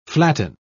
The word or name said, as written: flatten